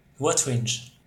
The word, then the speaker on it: Wotringe: Say your name again. – Wotringe. – Wotringe. I get it that time? – Yeah, like, perfect.